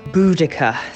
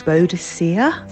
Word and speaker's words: Boudica: I pronounce it Boudica. – Boudica. – Boadicea. – Boudica. – I always thought it was Boadicea. – Boudica. – Boudica. – Boadicea. Boudica. Who knows? – I choose Boudica.